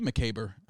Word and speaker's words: macabre: Ooh, it could be macabre. – Macabre? – It could be. – How do you say that word? – Macabre. I think you nailed it.